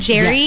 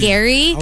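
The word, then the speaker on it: Gerry: We said Jerry, yes. But apparently it's Gary. Pronounced Gary.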